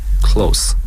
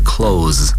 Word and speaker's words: close: 应该读成 close 而不是 close，因为 close 是动词， close 在这里是形容词。